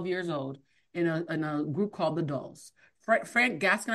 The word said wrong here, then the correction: Frank Gatson: Frank Gatson. – Gatson, there we go. I always get his name wrong because of all the T's and S's in the middle.